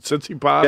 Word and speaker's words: Tsitsipas: Tsitsipas? – Yeah, yes. – You're going to look at Stefanos Tsitsipas on the other half of the bracket.